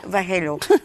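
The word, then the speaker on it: Vallejo: Irene Valerro, é assim que se pronuncia? Valerro. – Valerro.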